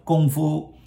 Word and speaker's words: Kung Fu: Yeah, that one actually is Kung Fu. Gong, not Kung. Gong is start from G. Kung Fu, first tone. Kung Fu. – Yes, that's also a very good point that it should be pronounced like with a G sound, not with a K sound.